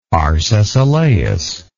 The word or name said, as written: Arcesilaus